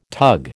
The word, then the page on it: tug: American English